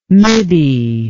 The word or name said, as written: middie